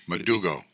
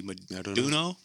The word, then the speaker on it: Modugno: M-O-D-U-G-N-O. – Madugo. – Would it be Maduno? – Is that what it is? Maduno. – Because you wouldn't say the G.